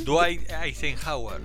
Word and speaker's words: Dwight Eisenhower: Ahí ayúdame con la pronunciación. – Dwight Eisenhower. – Muy bien.